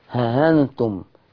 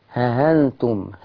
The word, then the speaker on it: ها أنتم: النهاردة عايشين مع ها أنتم، احنا عايزين نتعلم ننطقها صح، ها أنتم ها أنتم ها أنتم. عمل إيه ورش؟ حذف الألف اللي بعد الهاء وسهل الهمزة.